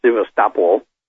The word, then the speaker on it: Sevastopol: And we were at the Black Sea at Sevastopol, or as they pronounce it, Sevastopol.